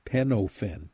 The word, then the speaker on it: Penofin: Andy and I have talked about this Penafin product, and again, somebody's going to say, "How do you spell that? P-E-N, like pen." Penofin, P-E-N-O-F-I-N, Penofin.